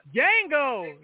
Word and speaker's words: Django: Django, the D is silent.